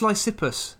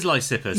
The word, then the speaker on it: Lysippus: So I am talking about, and I am definitely going to pronounce his name wrong, Lysippus, the famous Greek sculptor. – I think it's Lysippus. – Is it Lysippus? It is Lysippus. – Yeah, you put the emphasis slightly incorrectly, but that's okay.